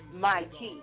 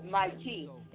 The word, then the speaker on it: Mahti: It's actually pronounced Mikey, M-A-H-T-I, Mikey, and it means loyalty.